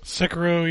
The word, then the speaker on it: Sekiro: Uh, was it Sekiro? Is that how you pronounce it? – Sekiro, yeah.